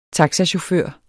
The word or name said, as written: vinterfoder